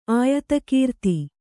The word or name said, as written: ಆಯತಕೀರ್ತಿ